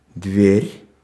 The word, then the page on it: дверь: /ˈdvʲerʲ/; dver